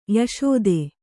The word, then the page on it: ಯಶೋದೆ: yaṣōde